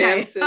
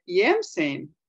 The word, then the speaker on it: Jamson: Do you say Jamson or did you just pronounce it that way for that so that people know how to spell it? – The American way, okay. Jamson. – I was going to say, have I been pronouncing it wrong all this time? – You're pronouncing it the right way.